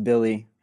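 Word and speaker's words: Willy: I've been all in on Willie, or spelled Willie, pronounced Billy, Hernan Gomez.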